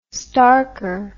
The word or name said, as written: schtarker